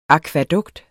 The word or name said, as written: akvædukt